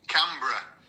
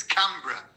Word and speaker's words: Canberra: It's pronounced Canberra, so like the bruh bit, not like bruh or bruhu or brahow. Oh, so it's Canberra, as if you were saying Edinburgh, but it's with a can in the front.